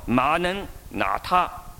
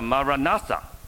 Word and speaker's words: Maranatha: 第三个词大家不一定很熟悉，也是全世界发音都一样的，叫马能拿他，英文叫 Maranatha，这是亚兰文。